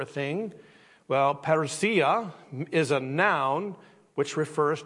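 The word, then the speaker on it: Parousia (παρουσία): And that's why this message is entitled today, Parousia is how it's pronounced. Parousia, it's a Greek word, but the one thing I found fascinating, it's a noun.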